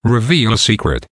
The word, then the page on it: reveal a secret: American English